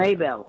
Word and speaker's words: Mabel: Could you spell that? – M-A-B-E-L. – Oh, Mabel. Okay, I'm glad that I asked you to spell Maybell. – That. Mabel. Oh, Maybell. Okay, that's a pretty name.